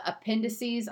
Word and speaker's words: appendices: And so when we were looking at that, I mean, some of the appendices, I never know how to pronounce that word. – Appendices. Good, yeah. That's perfect.